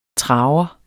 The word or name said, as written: traver